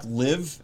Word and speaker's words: LIV: We're going to talk live. Is that how you pronounce it? Live? – L-I-V, yeah.